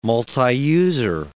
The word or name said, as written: multiuser